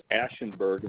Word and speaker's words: Ashenberg: The last name is Ashenberg, and it's spelled A-S-H-E-N-B-E-R-G. – It's A-S-H-B-U-R-G-E-N? – No, I'm sorry. It's A-S-H, and then E as in elephant, N as in nut, B as in bird, E-R-G, Ashenberg.